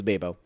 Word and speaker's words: Babo: I don't know why there's two B's, but my nickname back in the day was Babo. – Like Gabe? – Yeah, like, well, Gabe, babe, that's Babo. How I used to say my name as a kid. My name is Babo.